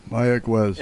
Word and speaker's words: Mayagüez: Mayagüez.